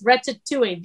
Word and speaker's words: ratatouille: I want to cook some ratatouille. Is that how you say it? – We were very close, ratatouille, very close, like ratatouille.